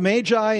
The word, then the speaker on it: Magi: The account of the Magi, and that is the correct way to pronounce it.